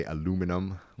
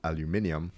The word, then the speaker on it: aluminium: So Jamaica is one of the top three producers of bauxite, which is used to make what Americans say aluminum, but it should be pronounced aluminium.